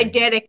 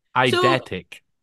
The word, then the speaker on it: eidetic: Eidetic memory. – Eidetic.